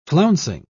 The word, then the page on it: flouncing: British English